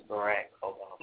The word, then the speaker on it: Barack Obama: Yeah, well, you're saying it wrong anyway. His name is Barack Obama.